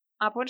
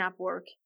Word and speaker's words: Upwork: Upwork se escribe U-P W-O-R-K.